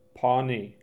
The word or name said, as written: Pawnee